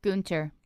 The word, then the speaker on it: Gunter: Do you pronounce his name Gunter or Gunter? – Gunter. – Okay. I've called him Gunter forever.